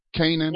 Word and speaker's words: Canaan: But they're also asking us to call or fax um, USP Kane, uh, Kane, I believe is how you pronounce it. Kane. Canaan. Yes. All right, Canaan.